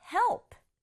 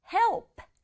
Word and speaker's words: help: Help. Help. 발음할 때 help 아니에요. Help 이렇게 두 박자 아니고 help. P의 소리는 p 정도만 나도록 help. Help 이렇게 소리 내주세요.